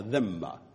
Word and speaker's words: dhimma: dhimma,